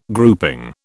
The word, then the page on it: grouping: British English